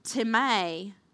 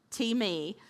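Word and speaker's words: T-me (τιμή): When I looked up the Greek meaning of honor of this word, the Greek word is time, which is spelt T-I-M-E. I know Bridget, right? Bridget's giving me the eyebrow. True story. T-I-M-E. That's not how it's pronounced. It's not pronounced time. It's T-me. T-me. I'm sorry. It's T-me. I wrote it out phonically correct. T-E-E-M-A-Y is how you say it, but T-I-M-E.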